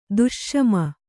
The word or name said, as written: ದುಷ್ಷಮ